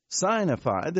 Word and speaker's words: signified: and he sent and signified it to by his angel to his servant John. The word signified, S-I-G-N, is the first syllable, sign, signified.